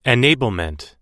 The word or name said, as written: enablement